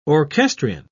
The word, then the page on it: orchestrion: British English